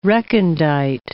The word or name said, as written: recondite